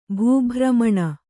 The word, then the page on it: ಭೂಭ್ರªÀುಣ: bhū bhramaṇa